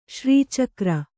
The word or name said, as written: ಶ್ರೀಚಕ್ರ